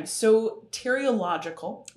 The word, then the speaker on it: soteriological: So you have, the first, which is this, I say it wrong every time. Soteriological. – That sounded good to me. – Close enough.